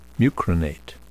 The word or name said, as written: mucronate